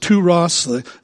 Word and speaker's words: Tyre: Arriving finally then on the eastern coast of the Mediterranean, they landed first at Tyre, or would have been pronounced Touros, the capital city of Phoenicia, where they spent 7 days.